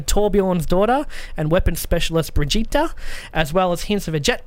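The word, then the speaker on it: Brigitte: After a series of teasers, Blizzard has confirmed Overwatch Hero 27 is Brigitta, it says Bridget, but it's pronounced Brigitta Lindholm, who will be available on the PTR at the moment.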